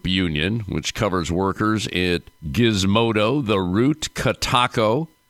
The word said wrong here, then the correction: Kotaku: I believe that's how you pronounce it, Jezebel, Lifehacker, and Jalopnik. Eric, did I get those names correct? Tell me. Help me out here. – Yeah. Kotaku. But yeah, otherwise great. – Kotaku. Okay, real good.